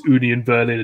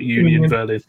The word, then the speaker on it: Union Berlin: I got corrected by Chris Williams, Bundesliga correspondent, on the pronunciation of that, so I have to do it as Union Berlin and not Union Berlin.